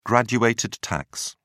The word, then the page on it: graduated tax: British English